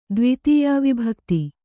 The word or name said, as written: ದ್ವಿತೀಯಾವಿಭಕ್ತಿ